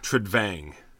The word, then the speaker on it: Trudvang: Trudvang. – I don't know how it's pronounced, but isn't there an N? – There is. V-A-N-G.